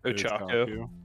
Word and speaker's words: Ochako: I think they've used Okocha. Can't say her name right. – Ochako.